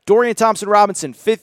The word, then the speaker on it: Thompson-Robinson: Again, Dorian Thompson-Robinson, Okay, I did say it right. I was going to say Robbins.